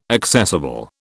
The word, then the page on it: accessible: British English